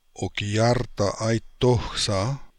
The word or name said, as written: Okiartaaitokhsa